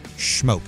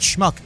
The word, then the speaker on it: Schmoke: Schmoke. Not Schmuck, but Schmoke.